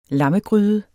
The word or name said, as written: lammegryde